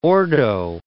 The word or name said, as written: ordo